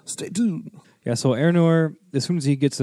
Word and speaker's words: Eärnur: How do you say it? It's Eärnur. – That's how I would say it, yeah. – Eärnur.